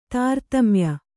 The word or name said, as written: ತಾರತಮ್ಯ